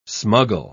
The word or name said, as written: smuggle